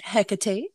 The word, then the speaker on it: Hecate: Well, as we just heard that little sound clip there, the little audio clip, it is pronounced Hecate.